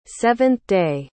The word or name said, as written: seventh-day